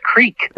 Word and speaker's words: creek: And she said, "No, it's not crick, it's creek."